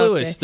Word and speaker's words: Louis: It's Louis. I just is it Louis? – Here. It says it rhymes with Chewy.